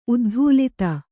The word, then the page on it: ಉದ್ಧೂಲಿತ: uddhūlita; Kannada